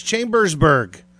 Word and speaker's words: Chambersburg: It's Chambersburg. – I see what you're talking about now. Yeah. – It's like that second syllable is longer than it should be. It should be a quicker syllable.